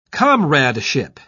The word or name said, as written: comradeship